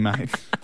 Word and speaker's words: Mayte: His daughter's name's Mate. It's spelled M-A-Y-T-E. – Yeah, it's Mate.